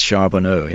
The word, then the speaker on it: Charbonneau: Her lover, Charbonneau, how am I pronouncing his name, William? – That's right, Jean-Baptiste Charbonneau, yes.